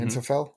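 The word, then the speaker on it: Hanfstaengl: Hansfingel, am I saying that right? – Yeah.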